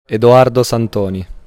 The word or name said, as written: Edoardo Santoni